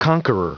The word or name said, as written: conqueror